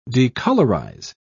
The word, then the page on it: decolorise: British English